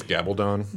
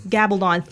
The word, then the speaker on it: Gabaldon: I believe it's Gabaldon. – Gabaldon. Thank you.